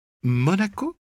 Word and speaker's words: Monaco: Récemment, je crois qu'ils sont allés dans votre pays, en France, la ville de Marocco, Manoco. Monaco ?» Elle me remercia, heureuse de rectifier son erreur, et répéta, afin de mémoriser la prononciation, « Monaco, Monaco, Monaco ».